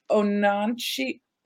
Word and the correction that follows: Oneonta: Ononcia State University. – Yeah, it's oh Onianta. – Oneonta, mm-hmm. there you go, State mm-hmm.